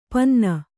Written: ಪಿನ್ನಿ

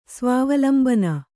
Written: ಸ್ವಾವಲಂಬನೆ

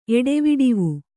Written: ಎಡೆವಿಡವು

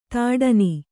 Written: ಥಟ್ಟನೆ

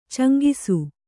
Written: ಚೆಂಗಿಸು

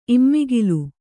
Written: ಇಮ್ಮಿಗಿಲು

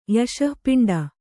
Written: ಯಶಃಪಿಂಡ

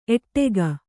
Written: ಏಡೂಕ

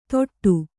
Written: ತೊಟ್ಟು

